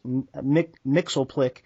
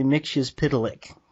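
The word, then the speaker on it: Mxyzptlk: I know how to actually pronounce the character that on the Super Friends they always mispronounced, Mixelplick. It's actually Mixius Pitalik.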